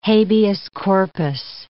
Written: habeas corpus

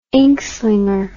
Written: inkslinger

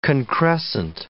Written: concrescent

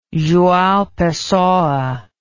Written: joao pessoa

